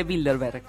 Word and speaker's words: Bilderberg: Por ejemplo, la casa de Rothschild, los Illuminati y el club de Bilderberg.